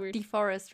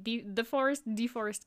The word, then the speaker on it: DeForest: It's like, I thought DeForest, right? With like an E. – So, like, the forest, like, when you say, oh, wow, there is the forest over there.